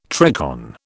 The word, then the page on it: tricorn: British English